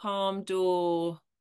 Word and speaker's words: Palme d'Or: Palme d'Or can obviously I pronounce that all in the English way.